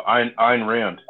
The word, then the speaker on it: Ayn Rand: Anne Ryan. How do you say her name again? I forgot. – Yeah. Oh, Ayn Rand.